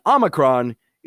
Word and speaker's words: Omicron: And Omicron is that really short one.